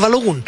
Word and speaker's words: Balogun: que yo hasta antes de la Copa del Mundo muy mal le decía Balogun, porque yo creía que así se pronunciaba, digamos, el norteamericano, entonces se termina en U, debe ser Balogun. No, es Balogun.